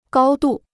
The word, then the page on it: 高度: gāo dù